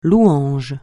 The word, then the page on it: louange: francais